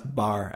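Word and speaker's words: barre: I think it's pronounced bar, actually.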